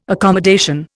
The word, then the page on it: accommodation: Indian